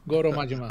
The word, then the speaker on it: Goro Majima: Goro Majima.